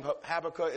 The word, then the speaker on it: Habakkuk: Just like you and I, again, in 626 B.C., there was a prophet by the name of Habakkuk. Some pronounce his name Habakkuk, as either is fine.